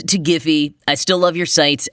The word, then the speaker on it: Giphy: the company I was discussing is properly pronounced Giphy, not Jiffy, like the campfire popcorn that I love.